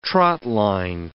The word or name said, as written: trotline